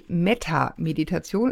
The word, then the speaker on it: Metta-Meditation: Du hast das erste Kapitel, ist die Metta-Meditation, ist das richtig ausgesprochen? – Ja.